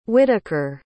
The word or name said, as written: whittaker